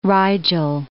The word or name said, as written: Rigel